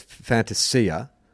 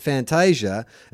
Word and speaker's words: Fantasia: So look, here's my understanding is that it came out that the actual pronunciation was Fantasia, and then BT has come out and said, yeah, but you Australianize it and make it Fantasia.